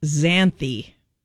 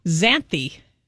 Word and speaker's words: Xanthe: It's spelled X-A-N-T-H-E and pronounced Xanthi. – Wow. – I kind of like that name. – It is kind of cool. – Xanthi.